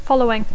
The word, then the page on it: following: English